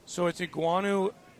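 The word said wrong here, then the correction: Igwenagu: Is that how you pronounce his name? Iguanagu. – Emil Iguanagu.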